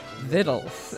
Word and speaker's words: Victuals: And her book is called, it looks like Victuals, but it's pronounced Vittles in the mountains.